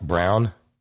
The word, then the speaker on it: Braun: You remember how yesterday Dave in the Blind in Taliban, Deanna, gave me the pronouncer for Taliban, Deanna Senator Mike Brown, like in Eva Braun?